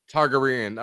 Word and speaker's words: Targaryen: This one I'm going to mess up. Targa Uren Theorem. Inspired by the Pythagorean theorem, so I'm probably saying it wrong. – Targaryen.